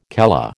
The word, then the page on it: calla: American English